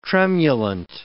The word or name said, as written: tremulant